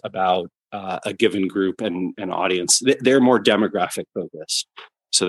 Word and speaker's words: Audiense: It's spelled a little funny. I think it's AUDIENSE, Audience.